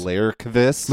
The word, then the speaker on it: Lagerqvist: Lagerqvist? – If your Swedish G's are pronounced as Y's, so it's actually Lagerqvist.